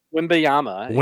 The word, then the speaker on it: Wembanyama: But Brandon Miller, he won't be number one because Wembanyama, how do you say it? – Wembanyama.